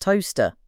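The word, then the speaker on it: Towcester: through Toaster, pronounced Toaster, and on towards Kettering and Corby.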